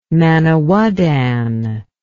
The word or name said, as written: manawydan